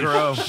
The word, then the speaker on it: La Croix: First of all, nice pronunciation there. – What is it? – La Croix. – Goddamn. – Oh, my God. – You need some culture.